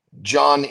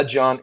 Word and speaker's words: Jon: But anyway, my Instagram, it's the John Andersen. So, but keep in mind, my name is spelled differently. So it's J-O-N, and then Andersen is A-N-D-E-R-S-E-N. So at the John Andersen, find me there.